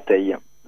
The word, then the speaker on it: Teil: Bonsoir, oui, alors tout d'abord merci de me recevoir sur Radio Anguin, et mon nom est bien Julien Tay, voilà, et bonsoir à tous les auditeurs.